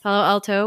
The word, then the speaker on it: Palo Alto: Palo Alto, where she continues to haunt university libraries.